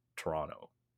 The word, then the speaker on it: Toronto: We at least I think when I listen to myself say it, I ignore a lot of the R's, so I think I end up calling it Toronto. – I don't pronounce the last T, right?